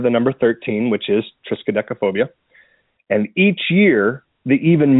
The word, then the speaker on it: Triskaidekaphobia: So Triskaidekaphobia. – I'm scared of that name. – Yeah, Triskaidekaphobia.